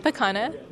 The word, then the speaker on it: Pacana: Pacana. – Can you spell it for me? – P-A-C-A-N-A. – And is Maria like M-A-R-I-A? – Yes, it is M-A-R-I-A indeed.